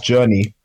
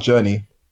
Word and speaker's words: Jurnee: I think it's pronounced Journey, but Journey? – You never know if these American names, right?